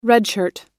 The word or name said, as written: redshirt